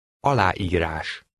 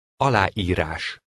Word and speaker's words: Aláírás: Number 20 has two vowels next to each other. Pronounce them distinctly. Read number 20. – Aláírás. Aláírás.